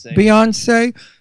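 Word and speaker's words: Beyoncé: Beyonce.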